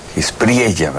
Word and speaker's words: Espriella: Espriella, ¿verdad? Se pronuncia con la doble L, quien apenas el viernes, me parece, asumió la presidencia de Colombia.